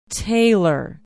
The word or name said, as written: taylor